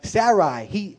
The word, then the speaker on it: Sarai: Let's go down to Sarai. Did I say it right, Tony? Yes. Sarai, which means princess.